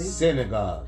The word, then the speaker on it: synagogue: So if Israel is the sack of God, yes, sack of God. That's a place. – See? Synagogue. – Synagogue. Yeah. I'm saying it wrong.